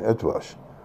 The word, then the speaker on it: Eötvös: We passed this statue recently of Baron Eötvös, yeah, pronounced in Hungarian, Eötvös,